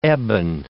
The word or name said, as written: ebon